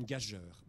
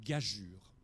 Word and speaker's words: gageure: Je le prononçais pendant des années une gageure. Mais ce n'est pas du tout comme ça qu'il faut le comprendre. Ça se prononce une gageure. À quoi sert ce G, cet E qui est à côté du G? Ça sert à dire ne prononce pas gagure, parce que G devant U, ça fait gu, comme chacun sait.